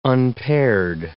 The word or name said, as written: unpaired